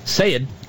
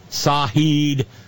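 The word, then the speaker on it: El-Sayed: El Sayed. Sayed is almost one word, like the word said with a long A. Or, to my ear, a very southern pronunciation of S-A-I-D.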